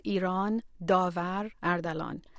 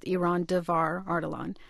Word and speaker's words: Iran Davar Ardalan: So in Farsi, my name is pronounced Iran Davar Ardalan. And so in English, it's pronounced Iran Davar Ardalan.